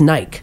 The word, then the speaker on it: Nike: please pronounce it correctly. It's Nike.